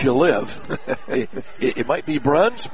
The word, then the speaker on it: Bruns: Handled there by Claire Bruns, B-R-U-N-S. This particular family pronounces it Bruns, and she drove to the basket, missed the shot, followed up and scored. Nice play by Bruns.